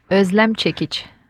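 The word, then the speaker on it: Özlem Cekic: Hvordan siger man egentlig det rigtigt? Åh, oh, det er virkelig, virkelig svært, men det udtales Øslem Cekic.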